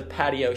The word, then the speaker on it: Patgio: Ladies and gentlemen, thank you for listening to the first episode of The Patio Show. Drop the G. The G is silent. P-A-T-G-I-O.